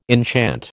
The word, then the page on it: enchant: American English